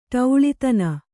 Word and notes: ಟೌಳಿತನ: kannada

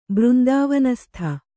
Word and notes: ಬೃಂದಾವನಸ್ಥ: břndāvanastha

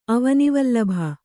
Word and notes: ಅವನಿವಲ್ಲಭ: kannada

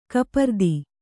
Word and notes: ಕಪರ್ದಿ: kapardi

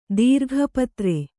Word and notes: ದೀರ್ಘಪತ್ರೆ: Kannada

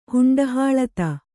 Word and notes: ಹುಂಡಹಾಳತ: Kannada

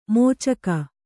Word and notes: ಮೋಚಕ: mōcaka

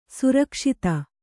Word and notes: ಸರಗಸ್ತು: saragastu

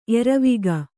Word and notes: ಎರವಿಕ್ಕು: eravikku; kannada

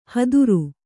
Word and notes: ಹದುರು: haduru; kannada

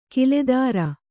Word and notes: ಕಿಲೆದಾರ: kiledāra

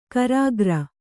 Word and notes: ಕರಿಕಾರೆ: Kannada

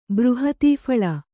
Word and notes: ಬೃಹತೀಫಲ: Kannada

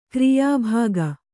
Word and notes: ಕ್ರಿಯಾಭಾಗ: kriyā bhāga

